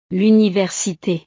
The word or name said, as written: l'université